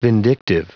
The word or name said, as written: vindictive